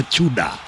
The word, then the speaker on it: Tudor: Anasema inaandikwa Tudor lakini inastamkwa Chuda.